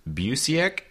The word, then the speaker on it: Busiek: So it was written by Kurt Busiek.